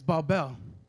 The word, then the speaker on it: Babel: The very word Babylon means, in Hebrew, to pronounce it is Babel.